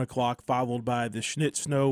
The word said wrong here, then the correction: Schnitt Show: We've got the Schnitt Show. I better say that right.